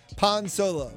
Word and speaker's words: Pan Solo: But part of it is pan rhymes with Han. So I'm using the Spanish pronunciation. Pan Solo, right? – I like that.